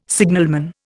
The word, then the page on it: signalman: Indian